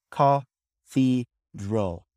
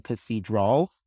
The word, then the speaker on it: cathedral: A bishop, and we pronounce this ca-thi-dral. Ca, or ca, ca-thi-dral. I know the A sound is there. You could pronounce it cathedral, and that's okay too. So you could say cathedral, cathedral. Either one sounds good. Ca-thi-dral. Both are okay.